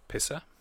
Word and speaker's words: PSSR: So it's in a band called that's spelt P-S-S-R, but it's pronounced Pisser.